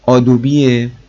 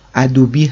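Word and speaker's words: Adobe: تلفظ صحیح آدوبیه که به اشتباه ادوبی هم گفته میشه که حالا ما سعی میکنیم توی این پادکست آدوبی بگیم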